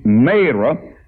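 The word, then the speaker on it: Marah: And if we'll back up just a little bit, when they came to Mara, some people call it Mara, but my Bible said it's a long A, Mara.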